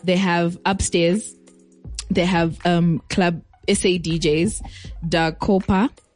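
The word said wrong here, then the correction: Da Capo: Dacapo, sorry, ish, these pronunciations.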